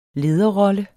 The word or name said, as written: lederrolle